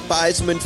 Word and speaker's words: Theismann: They wanted to do the rhyme. – Yeah. – Theismann for Heisman.